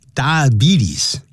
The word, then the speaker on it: diabetes: For today, we have this lesson 212, and you can see the title that has to do with some kind of disease known as diabetes. Diabetes, 这样发音，不要念 diabetes, betes, 多发 e 的长音。